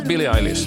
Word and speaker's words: Billie Eilish: Hur uttalar man Billie Eilish då? Billie Eilish. – Ja, Billie Eilish.